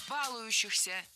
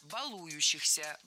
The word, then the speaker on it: балующихся: Балующихся. Неверно. Правильно. Балующихся. Балующийся. В этом причастии, как и в глаголе, от которого оно образовано, ударение не ставим на корень. Баловать, балуешь, балующийся.